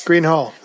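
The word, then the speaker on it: Gruene Hall: Green Hall. – Green Hall. Okay. – It looks like Groon, but yeah, Green Hall.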